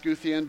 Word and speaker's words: Scythian: I know it doesn't look like it should be pronounced Scythian, but it is.